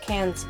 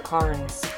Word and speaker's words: Cairns: That's right, it's pronounced Cairns, people, not Carnes.